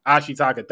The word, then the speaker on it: Ashitaka: Shitaka, I can always get the main character's name wrong. I'm sorry. Because he really carried the movie. Ashitaka, thank you, bro.